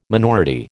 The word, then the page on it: minority: American English